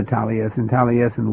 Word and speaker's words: Taliesin: Tell me about Taliesin. Am I pronouncing that correctly? – Yes, Taliesin.